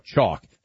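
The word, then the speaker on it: Choq: Chalk. That's C-H-O-Q, spelled with a Q.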